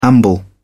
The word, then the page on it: amble: British English